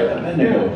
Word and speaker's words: Abednego: Like Daniel, Shadrach, Meshach, Abednego. I said it right, Abednego.